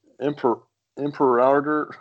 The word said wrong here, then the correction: Imperador: how do you say it? – Imperador. – Yeah. Imperador.